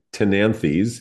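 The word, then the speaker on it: Ctenanthe: I've done very well in general with Calatheas or Calatheas, the related Tenanthes. This is one of my favorites because it's Cten Anthe. Tenanthe, the C is silent, closely related.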